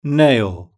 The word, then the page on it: nail: British English